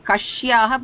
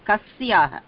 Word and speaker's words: kasyaha: So another pronunciation, if I can correct, is not Kashyaha, but Kasyaha. Sa.